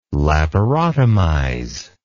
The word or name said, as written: laparotomize